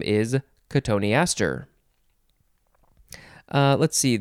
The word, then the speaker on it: cotoneaster: Next word is cotoneaster. Cotoneaster. You could also pronounce it, let's see, cottonister. I think that's the other way. Cottonister. It is spelled C-O-T-O-N-E-A-S-T-E-R. Cotoneaster. That's how I'm going to say it.